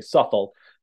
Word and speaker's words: Southwell: It's one of these mysterious English names where consonants drop out. Uh, so most, I think, people pronounce uh, like experts say "suffle."